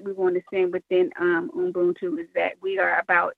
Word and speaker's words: Ubuntu: So it's Ubuntu. – And Ubuntu.